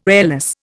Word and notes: airless: Indian

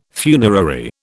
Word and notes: funerary: British English

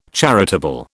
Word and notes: charitable: British English

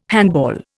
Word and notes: handball: Indian

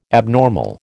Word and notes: abnormal: American English

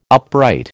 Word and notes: upright: American English

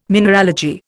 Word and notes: mineralogy: Indian

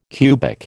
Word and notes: cubic: American English

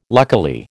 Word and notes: luckily: American English

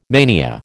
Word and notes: mania: American English

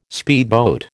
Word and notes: speedboat: American English

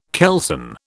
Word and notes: kelson: British English

